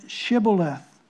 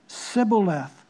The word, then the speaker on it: Shibboleth: And if he said no, then they would say to him, "Then say Shibboleth." And he would say Sibboleth, for he could not pronounce it right.